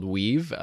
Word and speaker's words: Wiivv: Speaking of new technology, this is a new company, a new startup called Weave, obviously spelt WIIVV.